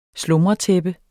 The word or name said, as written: slumretæppe